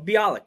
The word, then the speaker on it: Bialik: So, Amy Farrah Fowler, Mayim, I can't pronounce her second name, Mayim Bialik. – Yeah. Oh, Bialik, yeah.